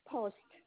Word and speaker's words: Post: S-H-U-C-H-U-A-N-N for Nancy. Children. – Okay, what's your last name? – Post, P-O-S-T-T for Tom. – P-O-S-T-T? – No, P-O-S-T, post, T for Tom.